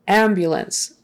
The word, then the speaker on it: ambulance: Ambulance, again, it's a noun and the stress is on the first syllable. Ambulance, ambulance, ambulance. So that B-U in the middle, again, there's a Y in front of that. Ambulance. It's not ambulance, ambulance. Often there's a kind of a Y